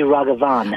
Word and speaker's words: Raghavan: It should be Raghavan.